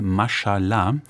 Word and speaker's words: Mashallah: Auch da geht es um Muslime in Deutschland, Titel Mashallah. Habe ich es richtig ausgesprochen? – Ja. – Ja? Ich war mal in Marokko und ein bisschen, da fiel dieses Wort sehr oft, Mashallah.